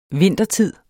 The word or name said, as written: vintertid